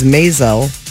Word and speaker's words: Maisel: Best comedy series, Atlanta, Barry, Good Place, Kominsky Method, The Marvelous Mrs. Maisel. – Yeah. Rhymes with nasal.